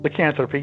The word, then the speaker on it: lycanthropy: And if I can say this correctly, I'm horrible at words, lycanthropy, am I saying that right? Lycanthropy? – Lycanthropy, yeah. – Okay, there we go. – You got it.